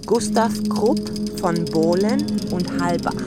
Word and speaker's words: Gustav Krupp von Bohlen und Halbach: Πώς προφέρεται κανονικά αυτό στα γερμανικά. Gustav Krupp von Bohlen und Halbach.